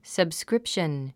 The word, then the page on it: subscription: səbskrípʃən; サブスクリプション